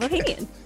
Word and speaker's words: Ohanian: And let's get to our talk with Sev Ohanian. Did I do it right? – Yeah, Sev Ohanian.